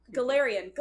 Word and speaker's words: Galarian: We have some new Pokemon to announce that they announced that there will be specific Galarian. Is that the name of it? I can never pronounce it. I'm so bad at this. – I saw them there. – Galarian! Woohoo, I was close.